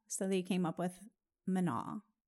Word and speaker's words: Manon: So, Manon. Did I say that? Manon? – Manon? Yeah, like they don't pronounce the last N.